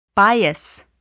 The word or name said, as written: bias